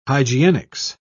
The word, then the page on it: hygienics: British English